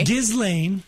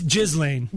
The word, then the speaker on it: Ghislaine: Ghislaine. – No, Ghislaine.